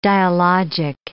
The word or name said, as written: dialogic